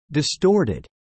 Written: distorted

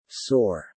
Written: soar

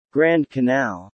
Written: Grand Canal

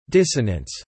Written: dissonance

